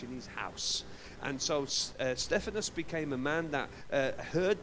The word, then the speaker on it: Stephanas: called Stephanus. That's his Greek word. And it's spelled S-T-E-P-H-A-N-A-S. So it's like Stephen, but don't be confused with Stephen from the Bible.